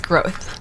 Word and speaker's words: Groth: It's Groth. – Valerie Groth, G-R-O-T-H.